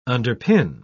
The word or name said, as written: underpin